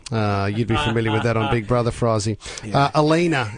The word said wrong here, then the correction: Olena: is that how I say her name? – Olena.